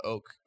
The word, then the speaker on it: OHK: So if you download my app, it's called Oak, but it's spelled OHK.